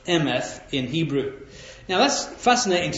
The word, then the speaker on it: emeth: And they say that the Hebrew word for truth is a word emeth, E-M-E-T-H. That's the transliteration from Hebrew into English. But they say that this word in Hebrew actually is a three-letter word, this word emeth that we pronounce that way, three-letter word.